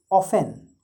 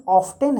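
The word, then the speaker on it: often: जैसे O F T E N ऑफेन तो अब वही ये सब क्या अमेरिकन क्या करते हैं उसको और आसान बनाने की कोशिश करते हैं तो उस आधार पर अगर देखें तो शायद O F T E N का जो प्रोनाउंसिएशन ऑफ्टेन है शायद ये अमेरिकन हो मैं कंफर्म नहीं हूँ लेकिन होना चाहिए यही तो उसके दो प्रोनाउंसिएशन है उसको ऑफेन भी पढ़ा जाता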